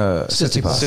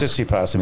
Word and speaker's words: Tsitsipas: I think the real the problem is if he has to play Zverev and Tipsy, how do you say his name? – Tsitsipas. – Tsitsipas.